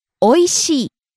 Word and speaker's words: おいしい: おいしい。 – Delicious. Tasty. – おいしい。おいしい。 – Rising on the second syllable, e, falling on the last syllable, e.